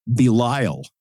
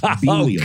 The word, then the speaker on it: Belial: The example I would always give, well, he pronounces the name of the devil as Belial. And I say Belial. – Oh, come on! – And I say Belial. – And they let you get away with that? – And I looked it up. And I have a book of proper pronunciations for biblical names. And I was right. It's Belial, not Belial.